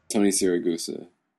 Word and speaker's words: Siragusa: Tony Siragusa.